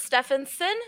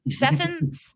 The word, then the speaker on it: Stephenson: Let's just talk about real quick the Rockies trade Jeff Hoffman, Case Williams to the Reds for Robert Stephenson. Did I say that right? – No. – Damn, Stephenson. Damn, my brain.